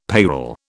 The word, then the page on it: payroll: British English